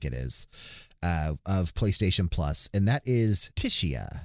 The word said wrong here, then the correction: Tchia: I think I say that, and, and I don't know. I never probably pronounced that right. Chia. Chia. Chia. Yeah. – Like a like a like a Shia pet.